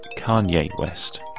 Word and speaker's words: Kanye: I said Kane West, and it's Kanye West.